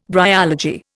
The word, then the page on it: bryology: Indian